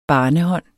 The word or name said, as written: barnehånd